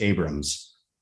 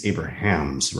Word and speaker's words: Abrahams: Jim Abrahams is that how you say his last name, or is it just Abrams? It would have to. I think it's Abrahams, right? – It's spelled out Abrahams. – Right. I think I've always heard as it's not like J.J. Abrams, but I, I think it, that's how I've heard it too.